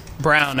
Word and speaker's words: Christian Braun: Brown. It was Christian Brown. That's how they pronounce it. – Oh, it's Brown. Sorry. Okay. – But it's spelled Braun. It made no sense.